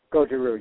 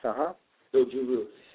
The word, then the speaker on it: Goju Ryu: Now, you, and, you know, forgive me if I, you know, pronounce this wrong, but you're a fifth-degree black belt in Okinawan Goju Ryu Karate, correct? – Goju Ryu, yes, Goju Ryu.